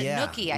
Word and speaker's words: gnocchi: Like, who makes gnocchi? How do you say that? – Gnocchi. – You called it gnocchi, I think. – Yeah. Yeah, gnocchi. It's, I later found out it's pronounced gnocchi. – Gnocchi? – G-N-O-C-C-H-I.